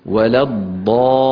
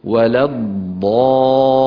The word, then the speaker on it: ولا الضالين: بالنسبة لللام لابد أن تكون مشددة، بارك الله فيكم. ولا الضالين. يعني لامان، ننطق لامين، لام ساكنة وبعدها لام مكسورة، انتبهنا. أعود فأقول ولا الضالين.